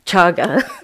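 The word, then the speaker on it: cāga: And the manifestation of kaja, is that how you say it? Chaga.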